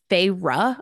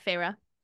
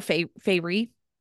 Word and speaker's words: Feyre: So her name is, and she pronounces it like on page 5 or 6, and it's Feyre, like Ruhra. – Yeah. Feyre. – I always call her Feyre. I don't know why, like a fairy, but Feyre.